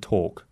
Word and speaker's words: talk: Walk and talk. So our words for today were people, little, call, well, fill, and exceptions where the L is not pronounced would be walk and talk.